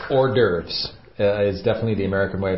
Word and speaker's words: hors d'oeuvres: Hors d'oeuvres. – Yes, and again, this is a French word, and when you read it in the dialogue, it doesn't look like the way I'm pronouncing it.